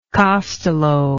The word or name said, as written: costello